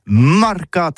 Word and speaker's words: marcat: Eh bien, dis ça à tous, mais le mercat de l'Uni ou le mercat des rebelles, mais en gascogne toulousain, c'est prononcé sur les marcats, avec un A marcat.